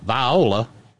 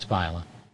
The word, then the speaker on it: Viola: And, of course, around here they pronounce it Viola. The same way those toxic little weenies in a can are called Vienna. It's Viola.